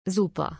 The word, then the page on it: Super: German